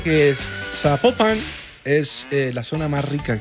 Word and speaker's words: Zapopan: Nos escriben los oyentes y que no se, no se pronuncia Zapopan, sino que se pronuncia Zapopan.